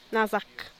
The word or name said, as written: nazak